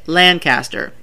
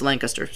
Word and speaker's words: Lancaster: Probably because they say it like this up north. Lancaster. – Yeah. – In the south, it's Lancaster. Sorry.